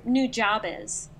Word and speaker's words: Nujabes: I've heard so it's like Nujabes.